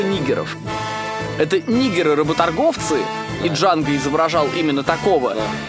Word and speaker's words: Джанго: Там снимается Франк Нера, он подходит в одной из сцен к Джанго и спрашивает, типа, «Как тебя зовут?» Он говорит, «Джанго». Он такой смотрит, «Джанго?» Как это читать? Он такой, «Д не читается, понял?» И Франк Нера такой, «Да».